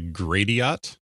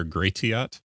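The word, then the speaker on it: Gratiot: So I'm going to say gradiat or gratiat. – Incorrect. You ready for some butchered French here? – Go ahead. – Gratiat. – Gratiat. Oh, that's part of that word. That's right. – Gratiat, Michigan. Yeah, it's just absolutely butchered French.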